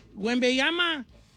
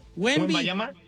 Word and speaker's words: Wembanyama: Don Cheto, a ver, dígame, ¿cómo se dice? – Wembanyama. – Wembanyama. – Wembanyama, como le dice todo el mundo.